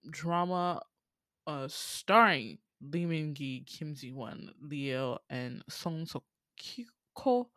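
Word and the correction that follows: Song Seok-gu (손석구): I need to look at his name in Korean because I swear to God I'd be saying it wrong. Song So-kyu-ko. Right, it's Song Seok-gu.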